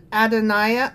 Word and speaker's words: Adonijah: Adonijah, had, I keep saying his name wrong because the J is a Y. Uh, Adon- Adoniah.